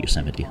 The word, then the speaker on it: Yosemite: So yeah, snow for the first time in Yosemite. Did I say that right? Is it Yosemite? Yosemite. I like Yosemite. That's better. Okay, number three.